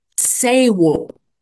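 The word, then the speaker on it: sāwol: The word is an old English word pronounced "sewol," and it actually has some like Germanic origins as well.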